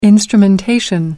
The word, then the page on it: instrumentation: American English